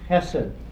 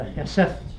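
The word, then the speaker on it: hesed (חסד): The Hebrew word hesed, the H is an aspirated H, and some pronounce the D like a soft TH. Walt Pilgrim from PLU. Walt likes to use the hesed pronunciation. They're both legitimate.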